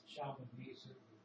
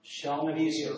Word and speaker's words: Shalmaneser: It's called the Black Obelisk of Shalmaneser III. Did I say that right? Shalmaneser? – I usually pronounce it Shalmaneser. – Shalmaneser, okay. I will take your advice.